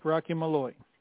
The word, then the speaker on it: Rocky Malloy: Did I say your last name right? Rocky Malloy? – Yeah, that's correct. Rocky Malloy.